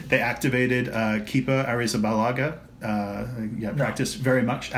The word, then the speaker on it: Arrizabalaga: Arrizabalaga. – Balaga. Yeah. – Arrizabalaga.